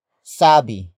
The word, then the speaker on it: sabi: It comes from the root word sabi. Sabi. Sabi. Sabi is spelled as S-A-B-I, which means a statement.